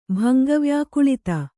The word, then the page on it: ಭಂಗವ್ಯಾಕುಳಿತ: bhanga vyākuḷita